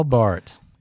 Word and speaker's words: Barth: Karl Barth. And for you people interested in spelling and pronunciation, his name is spelled B-A-R-T-H, but it is pronounced Bart.